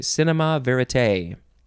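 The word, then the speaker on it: cinéma vérité: Cinema is cinema, and then the next word is V-E-R-I-T-E, with an accent on the last E that goes down on the left side and up on the right side. Cinema, I guess with the accent you have to say cinema verite.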